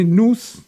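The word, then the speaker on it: Noûs: Alors, non pas « nous », le pronom personnel avec un « s » muet, mais « nous » avec le « s » prononcé, donc, et un accent circonflexe sur le « u ».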